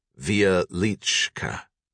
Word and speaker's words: Wieliczka: Wieliczka was a perfect example, pronounced Wieliczka.